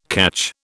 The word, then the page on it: catch: British English